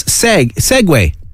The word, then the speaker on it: segue: Segway. – Segway, yeah. – Which is spelled S-E-G-U-E. So for people, it looks like Sieg in English or an anglicised version.